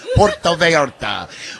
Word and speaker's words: Puerto Vallarta: That's how you say it, Corzo. Puerto Vallarta.